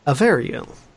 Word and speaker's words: Avariel: You are pronouncing it correctly. – Yeah, Avariel. Mm-hmm. Because they're avian. Avariel.